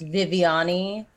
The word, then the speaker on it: Vivianne: In Portuguese, it's pronounced Vivianne, whereas it sounds and spelt like Vivian, so...